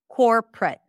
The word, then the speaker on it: corporate: Corporate, corporate. And this is how I would say it. Corporate. So no O whatsoever. And here that would be the or as in four. Cor, cor, cor. Core.